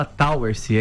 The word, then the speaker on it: Towersey: É Elton Towersey? – É, se escreve Towersey, mas se fala Towersey, é inglês.